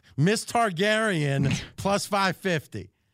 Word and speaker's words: Targaryen: Then we've got this fellow, Tyrannus Dargani.